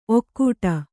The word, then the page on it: ಔಕಾಟ: kannada